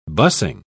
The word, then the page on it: bussing: British English